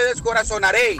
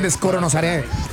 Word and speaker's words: descorazonaré: Filín, amor, se dice pajarito, te descorazonaré. – Ahí está, pero a mí se me quedó con el descoronosaré.